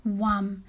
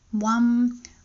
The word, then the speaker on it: VAM: The bija mantra for earth and the root chakra is LAM, L-A-M. The bija mantra for water and the sacral chakra is VAM, V-A-M, but the V is pronounced somewhere between V or W. Sometimes you see people say VAM, WAM, or usually it's something in between WAM.